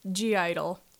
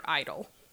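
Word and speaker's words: (G)I-DLE: G-IDOL. Which, technically G-IDOL is how they're spelled, but when you're saying it, the G is silent. Therefore, they're IDOL.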